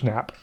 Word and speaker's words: Knapp: Do they pronounce it Knapp? – Yeah, that Knapp. It's George Knapp.